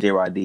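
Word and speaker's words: Jrahdi: That's J-R-A-H-D-I. And I pronounce it J-Rod D, even though it does look like it should say J-Rod D.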